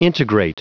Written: integrate

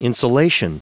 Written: insulation

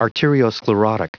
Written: arteriosclerotic

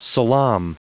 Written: salaam

